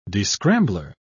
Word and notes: descrambler: British English

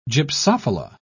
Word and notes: gypsophila: British English

